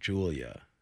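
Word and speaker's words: Julyah: She is a model. Julia Rose, I imagine, is how Julia. – You pronounce it. – There you go. Julia.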